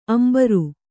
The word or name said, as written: ಅಂಬರಿ